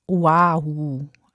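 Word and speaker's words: Oahu (Oʻahu): Dieses kleine umgedrehte Apostroph zwischen dem O und dem A, das heißt Okina und ist so eine Art Stimmabsatz, so wie bei Hawaii. Also eigentlich müsste es Oahu und Hawaii heißen.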